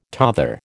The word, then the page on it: tother: American English